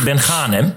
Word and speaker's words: Ben Ghanem: Ik moet de ehm, klemtoon op het tweede gedeelte van zijn naam leggen. Ben Ghanem. Zie je, ik ging alweer fout. Het is lastig. Samir Ben Ghanem.